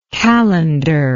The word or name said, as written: qalandar